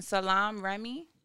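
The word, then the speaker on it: Salaam Remi: Salam Remy? – Yep. – Oh, I said it right? – Yeah. – Lit. – You don't know Salam Remy? – All right.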